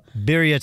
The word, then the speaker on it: birria: Birria tacos. – Birria taco. – Birria. Uh-huh. I said birria. But yeah. Then you were like, who? And then I kind of did the gringo pronunciation.